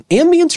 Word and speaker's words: ambiance: Their unmatched expertise and luxurious ambiance. Which one is it for this one? Ambience or ambiance? – Ambiance. – I've been saying it wrong the whole time.